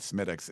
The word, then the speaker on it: Smithwick's: There was a Smittix. It's spelled like Smithwick's or something, but it's just they call it Smittix.